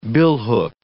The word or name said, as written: billhook